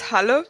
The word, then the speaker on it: Halle: It's Halle. – Halle? – It's like Halle, like Halle.